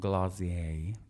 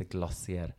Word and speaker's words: Glossier: Så från Glacier, det är så man ska uttala det enligt dem, inte Glacier.